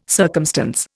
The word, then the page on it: circumstance: Indian